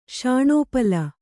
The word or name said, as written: ಶಾಣೋಪಲ